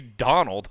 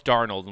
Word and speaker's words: Darnold: It should be Donald. And I'm like, and they're like, no, it's Darnold.